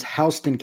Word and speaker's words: Houston: He goes to Warner Robins, Houston County. It's spelled like Houston, but welcome to the Deep South.